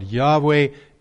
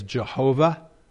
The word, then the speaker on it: Yahweh: Yahweh is our God. Yahweh is the name that God revealed himself to Moses and to the children of Israel. Sometimes it's pronounced, I think, incorrectly as Jehovah.